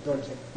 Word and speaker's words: Dorje: By the way, that's the only case in which the R is pronounced in the whole Tibetan language, is with diamond. Dorje.